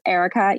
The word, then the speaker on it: Erika: But my Instagram handle is Erica, E-R-I-K-A, and then _ DePasquale Co.